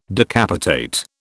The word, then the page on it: decapitate: British English